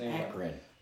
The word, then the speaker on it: Akron: I think I just started my first lease on with one of the companies I was with, and I was in Aiken, Ohio. – Sure, it's not Akron. – Or Akron, whatever. I can't say it right. I never can say those words. – It's Akron.